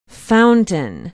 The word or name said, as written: Fountain